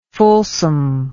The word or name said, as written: fulsomeness